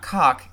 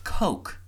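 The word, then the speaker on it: Koch: It's not Cock, it's Coke. Like the Coke brothers.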